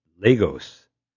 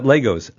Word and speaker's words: Lagos: I think the capital of Nigeria is Lagos. Not Lagos, Lagos. – Lagos. Like a long A sound is... – Maybe it's Lagos, but anyway, it's L-A-G-O-S.